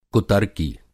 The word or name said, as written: कुतर्की